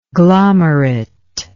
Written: glomerate

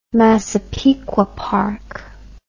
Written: Massapequa Park

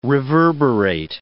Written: reverberate

